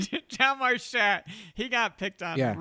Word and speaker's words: Hjalmar: I'm going to spell it for you. H-J-A-L-M-A-R. Hlammer? Hlammerstadt? I'm saying Hlammerstadt. – Jalmar. – Jalmar.